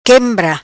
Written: quembra

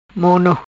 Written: Mono

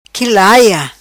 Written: qui laia

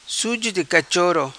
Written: Suzo Di Cachoro

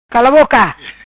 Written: Cala Boca

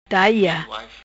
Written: Daia

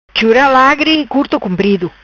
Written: Chura Lagri Curto Comprido

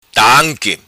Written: Tanqui

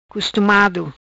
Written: Costumado

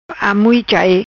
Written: Amuichai

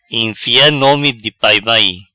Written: Enfea nome di pai mai

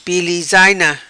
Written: Pilizaina

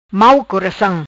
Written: Coracam Mau

